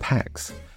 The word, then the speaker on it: Pâques: In France, Easter is pronounced Pax, and spelt P-A-Q-U-E-S.